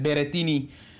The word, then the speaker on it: Berrettini: Of, nou hij, hij, hij, zat natuurlijk al in een, in een vrij prettig eh, gedeelte met, uh, met Berrettini.